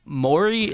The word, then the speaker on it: Maury: So this lady was on Maury. Is that how you say it? – Yeah.